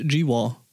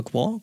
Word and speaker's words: GWAR: Dave äh, Brocky von der Band G-War. – Oder Gwar? Gwar wird die ausgesprochen.